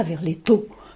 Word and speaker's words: Verletto: Alors, il le prononce comme ça, Verletto.